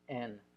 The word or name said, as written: en